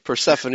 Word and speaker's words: Persephone: Persephone. Persephone is the usual pronunciation.